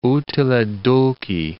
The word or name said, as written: utile dulci